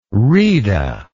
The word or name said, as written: rheda